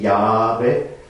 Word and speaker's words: Jahwe: Daher muss man sie also so lesen, Jahwe. Mhm. Also J-H-W-H geschrieben.